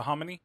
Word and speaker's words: hominy: So I noticed some people make menudo without the harmony. – Or without the hominy? – I always say harmony. I know. Hominy. I always say harmony. I can't help it.